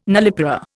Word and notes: nullipara: Indian

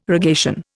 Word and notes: rogation: Indian

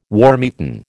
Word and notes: wormeaten: American English

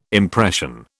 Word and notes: impression: British English

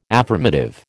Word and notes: affirmative: American English

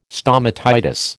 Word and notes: stomatitis: American English

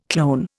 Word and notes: clone: Indian

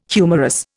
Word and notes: humorous: Indian

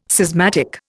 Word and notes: schismatic: Indian

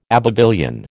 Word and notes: Abbevillian: American English

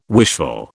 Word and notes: wishful: British English

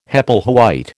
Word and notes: Hepplewhite: American English

